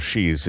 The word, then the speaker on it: Xi: Yeah, it's spelled XI, pronounced she, like she went to the store.